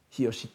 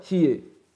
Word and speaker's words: Hiyoshi: On sait que le grand sanctuaire de Hiyoshi, Hiyoshi Taisha, était prononcé autrefois Hiei, C'est d'ailleurs une variante qui existe encore actuellement.